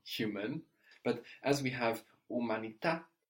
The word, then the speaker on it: umanità: And the H here is not going to be pronounced in Italian. – Umanità.